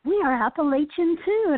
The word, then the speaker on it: Appalachian: So in this part of the region, in the Southern Highlands where I am in Western North Carolina, it's Appalachian, and we tell people it's pronounced. If you don't pronounce it this way, I'm going to throw an Appalachia, and that's how you remember how to pronounce it.